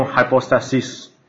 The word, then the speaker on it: hypostasis: 然后富兰姆就指出说，在早期教父的时候他们用 hypostasis，是不是这样 pronounce？ – Hypostasis 啊，hypostasis，